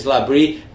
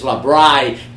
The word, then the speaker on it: Labrie: Foi muito bem elogiado o episódio do Dream Theater com James Labrie. Muita gente conversando, até o próprio Alan lá de Manaus, dizendo que entendeu a pronúncia, porque acho que ele falava James Labry.